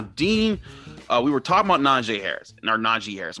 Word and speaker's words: Najee: But Najee Harris, which he confirmed on the Adam Schefter podcast this week, that, you know, that's how you pronounce his name.